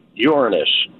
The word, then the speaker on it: Uranus: I'm feeling in a funny mood today, so it's correctly pronounced Uranus, Dom.